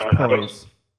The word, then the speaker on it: Proyas: Alex Proyas.